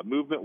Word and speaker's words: MVMT: Movement watches, when you see it in ads or on Instagram or whatever, is going to be spelled MVMT, but it's pronounced movement, was founded on the belief that style shouldn't break the bank.